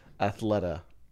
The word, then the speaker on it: Athleta: Athleta. – A-T-H-L-E-T-A.